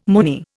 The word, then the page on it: moony: Indian